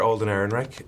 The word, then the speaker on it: Alden Ehrenreich: Mr. Alden Ehrenreich, it is an absolute pleasure to meet you.